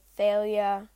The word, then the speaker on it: Thalia: You can pronounce it anyway. Thalia. I pronounce it Thalia, but they kind of added her in, and then she wasn't a huge part in the fourth book.